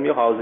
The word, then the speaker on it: Milhouse: Ranelli, eu não perguntei como é que falava o nome antes, espero que esteja falando certo, ou mais conhecido como Milhouse. Sim. – É o certo, é Milhouse mesmo.